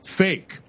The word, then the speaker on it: PHEIC: The acronym is PHEIC, and it is pronounced, in their words, it is pronounced fake.